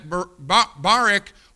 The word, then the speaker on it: Barak: Barak? Is it like Barack Obama? All right, then I'm going to pronounce it Barak.